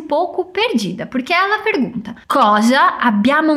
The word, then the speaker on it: cosa: Cosa mais uma vez, atenção à pronúncia. O O é bem aberto e o S tem som de Z.